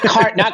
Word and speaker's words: carnet: No, not carne asada. It's spelled C-A-R-N-E-T. Like, think of carnet, but be like French and fucking bougie with it, and carne. So, like Tarjay.